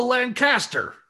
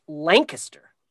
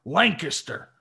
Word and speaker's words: Lancaster: House of Lancaster. – Actually, here it's pronounced Lancaster. – Oh, sorry. Lancaster. And the House of York. Did I pronounce that right? – Yes. Well done.